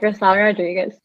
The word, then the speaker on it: Grisell Rodriguez: No, yeah, you got it, Grisell Rodriguez.